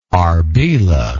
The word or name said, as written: Arbela